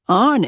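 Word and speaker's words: iron: Arn it. – The word had come out as one syllable, arn.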